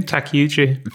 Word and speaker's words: Takeuchi: Takeuchi.